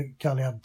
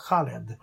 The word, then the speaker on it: Khalid: Ja, för det, man uttalar ju typ inte Khalid tydligen, utan Khaled.